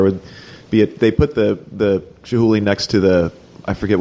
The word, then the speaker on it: Chihuly: But then, you know, I think his beef was with Chihuly.